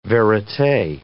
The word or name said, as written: verite